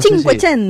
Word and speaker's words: Cinquecento: Esto es más que nada el Cinquecento. – El Cinquecento. – No sé si me olvido, ¿no? O sea, se pronunciaba perfecto. – Así.